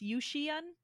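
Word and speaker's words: Yuxian (雨前): And the name comes from the Chinese word for before the rains tea, which is spelled much differently and pronounced Yuxian, which if you just keep butchering it in European, you get to Hyson.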